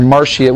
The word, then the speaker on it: Marcius: The fourth king was Ancius Marcius, or Marcius. We would have usually put an SH for the C, but Latin, all hard C's.